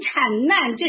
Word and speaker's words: 产难: 主耶稣特别用产，旧约先知们特别特别用产难啊，他用一个难，一个遭难的那个难，你不能读成难，它是一个难呐啊，受苦受难啊，患难，所以产难这是一个难。